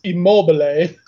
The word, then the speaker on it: Immobile: Of course, the Italian pronunciation is Immobile.